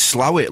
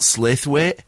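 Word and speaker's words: Slaithwaite: The best place is Slawit, like it's spelt Slathwaite.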